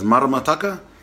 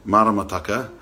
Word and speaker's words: maramataka: And kātiake, the word for today is maramataka. Not marama, not a long A, but a short A, maramataka, because marama is moon.